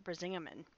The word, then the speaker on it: Brisingamen: And when I thought of Freya, I immediately thought of her necklace, which I found out the pronunciation is Brisingamen.